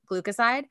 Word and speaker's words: glucoside: It also has a secondary form called ascorbyl glucide. I think it's how you say it. Glucoside.